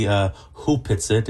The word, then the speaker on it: Hupitsit: It's written Opetsit, but it's Opetsit. Actually pronounced properly Hupitsit.